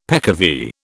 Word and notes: peccavi: British English

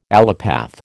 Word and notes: allopath: American English

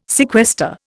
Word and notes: sequester: Indian

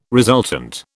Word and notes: resultant: British English

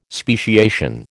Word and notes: speciation: American English

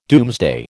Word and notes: doomsday: American English

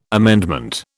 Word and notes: amendment: British English

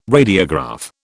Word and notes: radiograph: British English

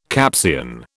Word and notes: Capsian: British English

